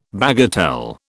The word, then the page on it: bagatelle: British English